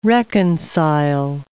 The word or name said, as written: reconcile